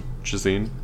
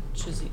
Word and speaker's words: Ghislaine: Ghislaine. – Oh, shit. Okay. – Ghislaine.